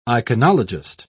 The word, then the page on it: iconologist: British English